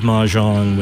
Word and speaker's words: Mahjong: How do you say that? Mahjong? – Mahjong.